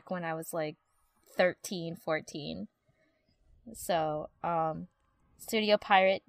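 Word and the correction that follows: Pierrot: did it. Piero. – It's pronounced Piero.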